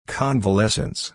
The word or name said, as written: convalescence